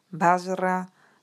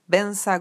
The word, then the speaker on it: Vajra: yo recito el mantra Om A Vajra Guru Padma Siddhi Hum, que los tibetanos pronunciamos Om Ahung Bensa Guru Pema Siddhi aspirando las H iniciales que para mí es verdaderamente Padma Sambhava y la bendición de todos los maestros en forma de sonido.